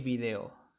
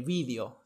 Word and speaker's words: video: Bueno, como ya dije, eh, en español obviamente la pronunciación es el video, ¿sí? El video, esa es la entonación. O en España también pueden, pueden decir el vídeo, ¿sí? El vídeo.